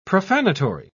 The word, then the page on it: profanatory: British English